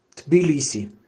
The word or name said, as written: tbilisi